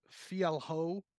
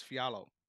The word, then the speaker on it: Fialho: It, it, it looks like Fia, uh, Fialho, but it's Fialo.